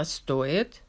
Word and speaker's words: стоит: The difference is stress. With the verb стоит, the stress is on the first syllable, like I just read. But with стоять, it's on the second.